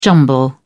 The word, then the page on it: jumble: British English